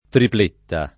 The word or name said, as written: tripletta